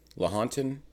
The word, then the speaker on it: Lahontan: Lahontan? Is that? – I believe that's how you say it, yeah.